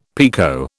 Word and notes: pekoe: British English

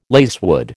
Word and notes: lacewood: American English

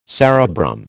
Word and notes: cerebrum: American English

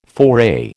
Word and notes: foray: American English

